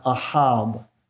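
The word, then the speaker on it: ahab (אהב): The Hebrew verb that means to love in the Hebrew language is the word pronounced ahab. Ahab. It's used 32 times in the Old Testament, the verb form of that word.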